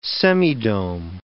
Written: semidome

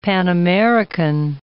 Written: Pan-American

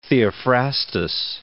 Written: Theophrastus

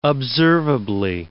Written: observably

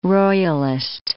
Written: royalist

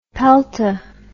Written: pelta

